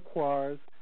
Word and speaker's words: Koire: You can read Rosa Quare's, some people pronounce it Quare, K-O-I-R-E, Rosa Quare, Behind the Green Mask.